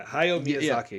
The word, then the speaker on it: Hayao Miyazaki: Hayao Miyazaki. Mm-hmm. Is that how you pronounce it? – Yeah. – Let's see. Yeah, Hayao Miyazaki.